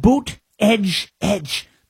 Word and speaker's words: Buttigieg: And you had Buttigieg, Pete Buttigieg, Buttigieg, Mayor Pete, it's pronounced Buttigieg,